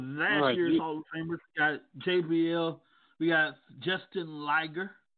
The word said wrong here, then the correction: Jushin Liger: I believe that's how I pronounce it. – Jushin Thunder Liger. – Jushin Liger.